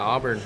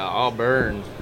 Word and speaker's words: Auburn: Yeah, Auburn, yep. – Auburn? – Yeah, Auburn. – Auburn. Yeah. Like is it like the football team, the town? – Just like it, yep.